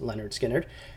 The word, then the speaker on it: Lynyrd Skynyrd: Could have picked a number of songs from Lynyrd Skynyrd's debut album, pronounced Lynyrd Skynyrd,